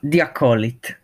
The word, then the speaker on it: The Acolyte: E eh, Haholit di non credo che la pronuncia giusta. – The Acolyte, credo, credo si chiami The Acolyte.